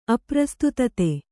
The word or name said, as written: ಅಪ್ರಸ್ತುತತೆ